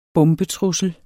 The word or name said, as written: bombetrussel